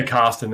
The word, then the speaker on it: Carston: Yeah, CARSTON. It's pretty, pretty hard sort of R.